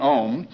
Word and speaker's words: Om: So we have this syllable, A-U-M. When you pronounce Om, it starts in the back of the mouth, ah, and then the sound mass fills the mouth, ooh, and then it closes at the lips, mm.